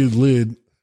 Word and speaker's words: Lwyd: I don't even know how you, L-W-Y-D? – Lyd. – Lyd? – Sure. – L-W-Y-D. It's from Wales.